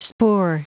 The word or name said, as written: spoor